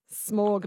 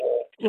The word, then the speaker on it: Smaug: Smaug.